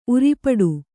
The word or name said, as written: ಉರಿಪಡು